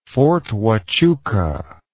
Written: fort huachuca

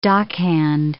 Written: dockhand

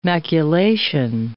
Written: maculation

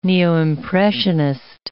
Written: neo-impressionist

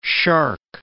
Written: shark

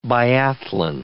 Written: biathlon

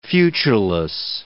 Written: futureless

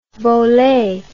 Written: volet

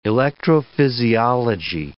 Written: electrophysiology

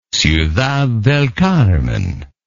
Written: ciudad del carmen

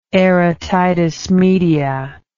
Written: aerotitis media

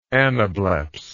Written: anableps

